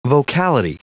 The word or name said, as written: vocality